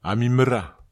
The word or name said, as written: amimera